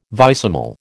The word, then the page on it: vicesimal: American English